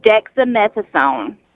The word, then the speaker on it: dexamethasone: how do you say this? – It's dexamethasone.